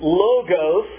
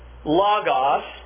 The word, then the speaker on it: logos: It is either pronounced logos or logos.